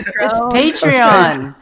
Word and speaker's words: Patreon: Patron. – Come on, it's Patreon. Get it right.